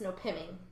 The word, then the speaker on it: Nopeming: Okay, it's spelled Nopeming, but apparently it's pronounced Nopeming.